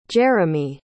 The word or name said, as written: jeramie